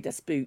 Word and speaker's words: Das Boot: Next one also came out the same year, Das Boot. Or if you pronounce it the English way, Das Boot.